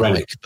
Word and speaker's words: Irenic: Irenic, but as in like Irene.